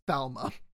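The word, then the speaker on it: Falme: So Nynaeve pronounced the city Falm. Okay. When in the audiobook, I've heard it as Falma.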